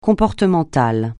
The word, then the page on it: comportemental: francais